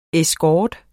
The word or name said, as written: escort